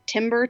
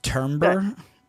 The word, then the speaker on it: timbre: It timber, timbre. I don't know how to say it. – But I think like, it's timbre.